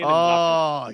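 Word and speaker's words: Mbappé: Yeah, Kylian Mbappe.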